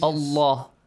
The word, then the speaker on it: الله: The lam and the word Allah. It has to be heavy. – Heavily okay. Pronounced. Okay? Yes? – Yes. – Allah. لَعْنَةُ اللَّهِ. Right? Not لَعْنَةُ اللَّهِ. Because there is a dhamma sound before the word Allah.